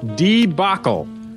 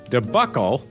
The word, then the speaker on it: debacle: He writes, To this honorable court, the plaintiff seeks summary judgment declaring that the proper pronunciation of D-E-B-A-C-L-E is debacle, with the stress on the second syllable. So that's debacle, not debuckle, with the stress on the first syllable.